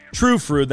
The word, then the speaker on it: Trü Frü: Trü Frü, that's T R U F R U. Uh, they've got the little umlauts over the U's, so you know that you're supposed to pronounce it with that ooh noise.